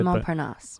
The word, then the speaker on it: Montparnasse: Montparnasse. – Exactly. – I'm not putting the French twirl on it, but there you have it.